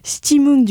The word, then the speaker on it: Stimmung: Maintenant, je le prononce bien, maintenant qu'on a reçu le programmateur du festival. Stimmung du compositeur allemand Stockhausen par l'ensemble Oxymore.